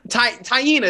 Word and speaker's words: Tiana: Tyena is the girl name. Tyena. My bad, tuna fish. – I thought it was Tiana. – It's spelled Tiana, but her name is Tyena.